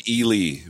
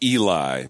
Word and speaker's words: Ely: Born in Ely, Minnesota, commonly pronounced Eli by those not in the know.